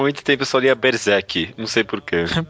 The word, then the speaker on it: Berserk: Eu Porque todo mundo fala Berserker. – Mas é, não tem air no final.